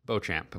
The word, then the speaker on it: Beauchamp: He is number 20 in the class of 2020, and his name is, I'm trying not to butcher this, Marjon Beauchamp is probably how that's pronounced. – Beauchamp, of course.